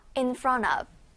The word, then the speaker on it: in front of: 읽을 땐 살짝 연음이 있어요. in front of.